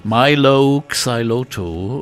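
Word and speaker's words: Mylo Xyloto: würdest du das aussprechen? Milo Xyloto, würde ich sagen, äh, bedeutet überhaupt nichts.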